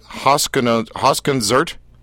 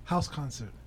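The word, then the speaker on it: Hauskonzert: Is it Hausconzert? – House concert. – Seriously? – It's German.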